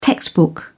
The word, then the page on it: textbook: British English